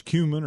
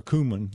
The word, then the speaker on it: cumin: whether it's cumin or cumin. Either one seems to be okay.